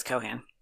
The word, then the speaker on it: Cohan: Um, you always say Cohan. Why don't you say Cohen? – Because he doesn't say Cohen. He says Cohan. – He really does? – Yeah. He emphasizes the second syllable?